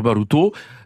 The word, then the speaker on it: Baruta: Alors, en fait, il s'agit d'une forme méridionale de Baru, B-A-R-U, ou Occitane. – Oui, le prononcer alors Baruto, parce qu'effectivement le A en occitan se prononce O, et donc ça devient Baruto. Ça s'écrit Baruta, ça se prononce Baruto.